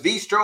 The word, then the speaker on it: Veestro: I thought it was a great name, uh, and, and I thought, man, this is, you know, it, it sounds like bistro, but it's got that emphasis on the V so it's Veestro.